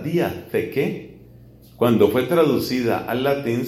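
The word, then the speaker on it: diatheke (διαθήκη): La palabra alianza en griego se dice diatheke. Eso se escribe como se oye diatheke, pero con th, diatheke.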